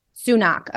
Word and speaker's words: Sunak: Sunak, okay. – Yeah, Joe Biden got it wrong recently. He mispronounced it, but our Prime Minister's called Rishi Sunak, and he has advocated central banking digital currencies for quite some time.